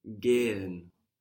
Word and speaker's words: gehen: Gehen. – Gehen. – Gehen. – This is spelled G-E-H-E-N. And mm-hmm. That H just stretches the vowel out.